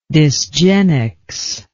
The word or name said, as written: dysgenics